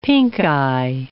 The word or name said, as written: pinkeye